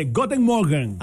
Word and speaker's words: Guten Morgen: No se dice así. – ¿Cómo se dice? – Yo le iba a decir antes, pero se dice así. – ¿Cómo se dice? – Se dice Guten Morgen. – A Guten Morgen. – Guten Morgen. – Guten Morgen. Okay, cuando. – Okay. Se escribe Guten, pero okay. Creo que no se pronuncia U.